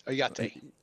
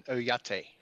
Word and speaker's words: Oyate: Did I even get that close? – Very close. Oyate. – Say it again. Say the whole thing. – Sisseton Wahpeton Oyate.